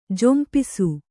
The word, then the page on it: ಝಂಪಿಸು: Kannada